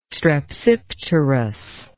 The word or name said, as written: strepsipterous